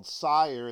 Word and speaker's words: Syrmd: It's a company called Syrmd.